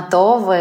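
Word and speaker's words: Tove: Просто в шведском это О, она произносится более так вот Това. То есть она между, наверное, таким средним нашим открытым О э, и, и, и нашим таким У.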